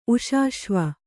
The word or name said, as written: ಉಷಾಶ್ವ